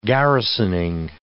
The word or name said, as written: garrisoning